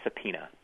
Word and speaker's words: subpoena: And this is my favorite, because the P sound is spelled in such a bizarre way. – Oh, a subpoena.